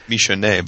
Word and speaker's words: Michonne: But the governor with Michonne, is that how you pronounce her name? – I think Michonne, but yeah.